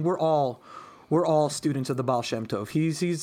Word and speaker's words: Baal Shem Tov: Baal Shem Tov.